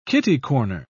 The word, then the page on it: kitty-corner: British English